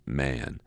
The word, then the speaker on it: Mahon: He had a Roman nose, a snow-white long beard, and his name was Mahon, but he insisted that he should be pronounced Man.